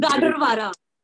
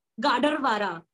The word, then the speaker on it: Gadarwara: Gadarwara. – How do you spell it? – Gadarwara. – G-A-D-A-R-W-A-R-A.